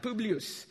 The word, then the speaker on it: Publius: That's how they say it in Italian, Publius.